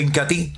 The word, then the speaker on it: Incatea: Se escribe Incatea, pero se pronuncia Incati.